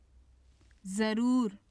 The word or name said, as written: zarur